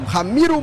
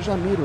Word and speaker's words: Jamiro: Mas a falta foi marcada, é de longe, é quase no círculo central, no Ramiro Monteiro. Se escreve Jamiro Monteiro. Como é que a gente pronuncia, hein? Porque é português, né? Cabo Verde fala português. Acho que é Jamiro, né? Jamiro Monteiro.